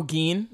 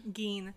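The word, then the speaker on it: Gin: And then like Gideon, is that how you say his name? Who? The creepy guy with the smile. – Oh, Gein. – Gein.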